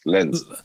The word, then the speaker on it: Lens: Nee, Lille, nicht Nantes. Also, L-E-N-S, Lenz.